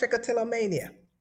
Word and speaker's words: Trichotillomania: You said it correct. Trichotillomania.